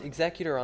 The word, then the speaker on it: executor: It's pronounced executor on there.